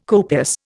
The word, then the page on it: copious: Indian